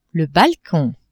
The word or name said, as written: balcon